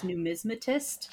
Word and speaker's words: numismatist: And the word you were looking for was numismatist. – Thank you. Right, I knew there was an extra syllable in it.